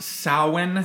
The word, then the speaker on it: Samhain: Is it about Samhain? – It's pronounced Samhain.